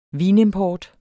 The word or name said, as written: vinimport